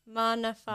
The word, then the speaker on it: Maana Faay: did I mess it up? Is it Maana? – Maana Faay. – Maana Faay, oh wow. And I'm Somali as well. the novel, Maana Faay, no longer, the Mogadishu that he describes no longer exists.